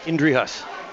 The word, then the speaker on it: Inderhaus: Steve Inderhaus, that's how your name is pronounced to me. But how is it really pronounced? – Inderhaus. – Inderhaus. – Yeah. – I like that better.